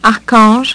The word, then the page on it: archange: French